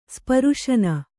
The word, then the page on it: ಸ್ಪರ್ಶನ: kannada